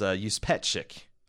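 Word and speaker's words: Juspeczyk: So Lori, and I did not know how to pronounce this, but it's Yuspetchik. Is the actual pronunciation.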